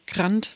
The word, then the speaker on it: Grant: Habe ich es richtig ausgesprochen? Grant, wie grantig? – Genau so.